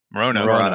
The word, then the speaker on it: Moreno: Moreno.